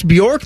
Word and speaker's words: Bjork: Just for Ross Bjork, the Bjork man? – Yeah. Yeah. Apparently the J is silent, but I am not, because I did about 7 hits today.